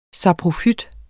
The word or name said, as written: saprofyt